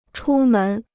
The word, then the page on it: 出门: chū mén